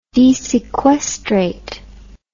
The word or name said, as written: desequestrate